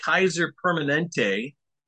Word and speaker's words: Kaiser Permanente: We are talking specifically about a huge case you're both taking on against Kaiser Permanente.